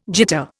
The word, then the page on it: jitter: Indian